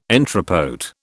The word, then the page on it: entrepôt: British English